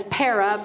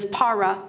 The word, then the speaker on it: para: Para, some people say it as para, but the Greek pronunciation is para.